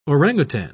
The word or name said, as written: orangutan